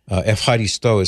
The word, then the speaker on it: Ευχαριστώ: Ευχαριστώ is how it's pronounced. I now know from my travels to Greece, I've learned this, that Ευχαριστώ is the way, at least the way an American would pronounce it in modern Greek.